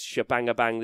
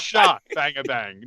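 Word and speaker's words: Shabangabang: I'm going to say that it's spelt, it's not shebangabang, it's sha. So I'm going to, let's go, S-H-A, bang, ah, bang. That's so it's shot bang a bang, not she bang a bang.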